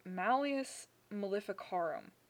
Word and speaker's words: Malleus Maleficarum: the Malleus Maleficarum was written, and I know that pronunciation is right because I had it double checked.